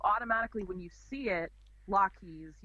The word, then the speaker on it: Lochees: He confirmed that I was pronouncing it right as Lockheed's. – Well, yeah, and if you listen to the audio tapes, which I recommend, it's Lockheed's. Lockheed's.